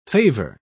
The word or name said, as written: favor